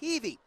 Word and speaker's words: Heavey: His name is spelled like Heavy, but it's pronounced Heavey.